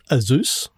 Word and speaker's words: Asus: It's actually pronounced Asus.